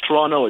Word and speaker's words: Toronto: Yeah, it's Toronto. It's the second T is silent.